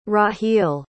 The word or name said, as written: rahil